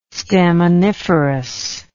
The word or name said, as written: staminiferous